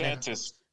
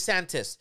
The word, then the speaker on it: DeSantis: If I believe she has presidential aspirations, and the thing is, the one thing I can say about the Santos from Florida, how you pronounce the governor's DeSantis. – Name? DeSantis.